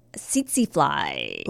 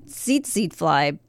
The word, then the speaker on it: tsetse: We are going to discuss how to survive an unfriendly insect, that insect being tsetse fly.